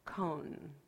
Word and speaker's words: koan: It's spelled K-O-A-N, koan, or koan.